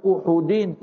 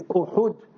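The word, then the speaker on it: Uhud (أُحُد): Hadith Bukhari Sharif, Jabal Uhudin is Uhud with Dhammil Ha, not Uhud. We must learn to pronounce the names correctly.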